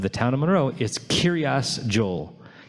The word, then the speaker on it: Kiryas Joel: Kiryas, which is a Yiddishization of the word Kiryat, which means village of, and Joel is actually, interestingly, an English pronunciation of his name, Yoel, or Yoelish, or Yoeli.